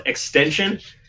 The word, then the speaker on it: XXXTentacion: So, it's pronounced extension.